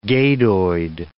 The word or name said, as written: gadoid